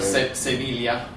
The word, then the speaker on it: Sevilla: Sorry, Sevilla. We have to pronounce it properly, otherwise, some of our listeners get a.